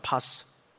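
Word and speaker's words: 파스 (πᾶς): 이 모든 병과 모든 약한 것, 이제 헬라 음역으로 파스라 그래요. 파스. 이게 멍들어오면 붙이는 그런 파스가 아니고 헬라 발음이 파스예요.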